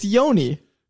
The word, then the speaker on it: Joni: Joni. It's Yoni. – Is it? – It's pronounced Yoni.